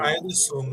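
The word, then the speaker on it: Ayo Dosunmu: me ajude na pronúncia, é o Ayodosumno, né? O... Ayodosumno, ah, é isso aí.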